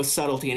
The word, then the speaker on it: subtle: No, I get, like, you know, oh, no subtlety anymore, but I'm like, I don't know, man. You know, subtle has a silent B in it? – Mm-hmm.